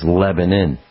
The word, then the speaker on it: Lebanon: Spelled Lebanon, L-E-B. Oh, Lebanon. Lebanon is how it's spelled. It's pronounced in the local vernacular as Lebanon.